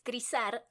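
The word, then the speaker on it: Krysař: La primera es Crisar. Se escribe K-R-Y-S-A-R.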